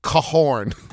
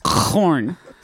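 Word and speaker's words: Korn: What if we just started pronouncing the band's name Cahorn? – Corn.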